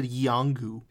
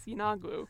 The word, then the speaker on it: Yeenoghu: Y-E-E-N-O-G-H-U. – I would have said Yangu. – The wiki had a pronunciation guide. It was Yinagu.